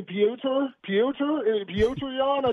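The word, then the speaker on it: Petr Yan: And do you call him Peter the way that Dana does, or Petur? How do you say his first name? Because I've heard it pronounced probably five different ways. – Yeah, I think it's actually Piotr Jan. I think it's how he actually says Piotr.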